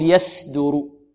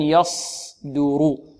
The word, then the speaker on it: يصدر: البعض ربما لا يضم شفتيه فيظن أنها سين، يقول يص، ربما نقول له إذا أردنا أن ننطقها سين فنقول يصدر، هذه سين، واضح. إذن يصدر، دو، والدال مرققة، يصدر، رو، والراء مفخمة.